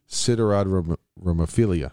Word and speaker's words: siderodromophilia: And you're going to correct me if I say it wrong, but try it, right? And the phenomenon of siderodromophilia. – Siderodromophilia.